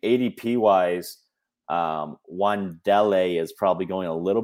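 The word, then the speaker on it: Wan'Dale: I think Matt Corral or Wandele. Did I say that right? – Wandele, correct.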